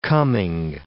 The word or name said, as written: coming